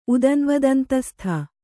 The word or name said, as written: ಉದನ್ವದಂತಸ್ಥ